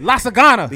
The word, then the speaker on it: lasagna: Lasagna. Where's the G? – It's silent. – Lasagna.